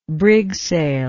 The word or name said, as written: brigsail